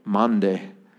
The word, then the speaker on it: Maundy: Because I never pronounce this correctly. Monday. Whatever. M-A-U-N-D-Y.